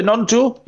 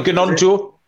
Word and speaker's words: Gnonto: Are you a silent G there, for Nonto? You're not a Gnonto? – It's not a Gnonto, no. It's Nonto for me.